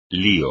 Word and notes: lío: Spanish

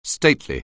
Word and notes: stately: anglais

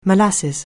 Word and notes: molasses: anglais

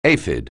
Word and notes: aphid: anglais

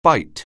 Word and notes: byte: anglais

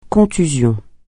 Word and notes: contusion: francais

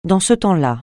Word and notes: dans ce temps-là: francais

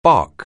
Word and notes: bark: anglais

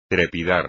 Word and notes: trepidar: espagnol

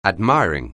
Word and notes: admiring: anglais

